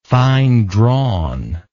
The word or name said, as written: fine drawn